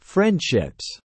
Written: friendships

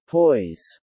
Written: poise

